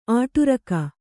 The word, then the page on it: ಅಡುರ್ಕೆ: kannada